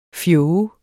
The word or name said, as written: fjog